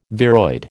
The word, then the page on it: viroid: American English